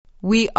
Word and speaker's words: We: دقت کنید که وی نگید به تلفظش دقت کنید لبا باید کمی قنچه بشه بگید ما هستیم We are We are ما چی میشه؟